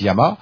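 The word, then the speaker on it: Yama (山): en japonais ça se prononce Yama, mais euh, c'est exactement la même chose, c'est la montagne.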